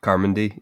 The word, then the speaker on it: Carmody: We tried to decide how to say her name. Miss Carmody? – Carmody. – Carmody. Yeah. Miss Carmody, who is just awful.